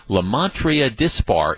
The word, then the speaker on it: Lymantria dispar: The Entomological Society of America has announced that what has commonly been known forever as the gypsy moth, that is a species known as Lymantria dispar in Latin. I think my late Latin teacher, Juanita Bonham, and the late great Juanita Bonham, would have been proud of my pronunciation there.